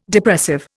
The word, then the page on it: Depressive: Indian